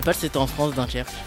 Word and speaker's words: Dunkerque: Sur TMC, à 21h25, c'est Dunkerque. C'est comme ça que ça se prononce. – Oui, c'est ça, c'est Dunkerque, oui.